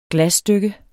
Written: glasstykke

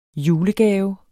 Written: julegave

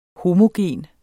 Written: homogen

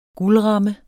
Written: guldramme

